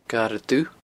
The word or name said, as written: Garadh Dubh